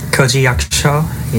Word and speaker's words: Koji Yakusho: Koji Yakusho, yeah.